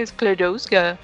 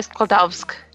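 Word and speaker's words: Skłodowska: Eles vão falar Sklodowska. – É, e então. o filme tá sendo produzido em alemão, então pode ser que não seja tão igual, mas pelo que me falaram, a pronúncia é diferente da do alemão original, então deve ser polonês mesmo, meio assim pode puxado ser. Pro polonês.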